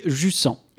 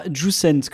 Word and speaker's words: Jusant: Ce jeu, c'est Jussan, et oui, Jussan et pas jussent comme je l'avais prononcé dans ma chronique abordant les démos.